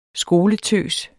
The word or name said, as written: skoletøs